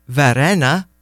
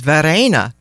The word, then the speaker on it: Varenna: All these years I've known you, 27 and counting, you thought Varenna was Varena because I just mispronounced it. Now we know better.